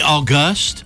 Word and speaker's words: August: August panel. – Isn't language fine. Shouldn't it be August? – Yes. – You didn't put the accent on the right syllable.